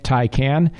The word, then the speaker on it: Taycan: This was a gray metallic Taycan. It's pronounced Taycan, but it's spelled T-A-Y. C-A-N, and it looked really, really sharp, as you would expect an electric Porsche to look.